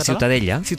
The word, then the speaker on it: Ciutadella: ¿cómo se pronuncia? – La Ciutadella. – Ciutadella. Bueno, pues Ciudadela también en castellano.